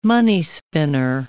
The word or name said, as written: money-spinner